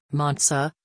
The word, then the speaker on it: Monza: Associazione Calcio Monza, Italian pronunciation, Monza, listen, is a professional football club that is based in Monza, Lombardy, Italy.